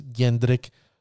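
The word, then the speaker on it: Jendrek: It's actually spelled T.S.P. J-E-N-D-R-E-K, Jendrick, but he pronounces Yendrick, so I will pronounce it T.S.P. Yendrick.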